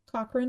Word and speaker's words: Cochrane: Other characters that were not discussed but are a part of this movie is Rory Cochrane? – Cochrane. – Is that how you say it? Cochrane? Oh, okay. That makes sense.